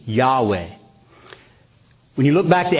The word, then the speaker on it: Yahweh: Yahweh, it would be the Hebrew pronunciation of that.